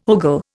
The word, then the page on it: ogle: Indian